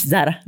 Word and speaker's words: Zara: It's very Spanish, Zara, because that's how they say it here.